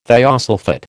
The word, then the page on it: thiosulphate: American English